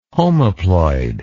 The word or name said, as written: homoploid